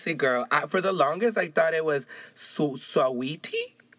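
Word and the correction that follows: Saweetie: because it's spelled S-A-W-E-T-I-E. And I was like, Sawiti, Sawiti. Do you know that song by Sawiti? And somebody was like, bitch, it's Sweet Tea.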